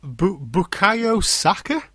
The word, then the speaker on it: Bukayo Saka: Bukayo Saka? Have I pronounced that right? – Yeah, It was an own goal.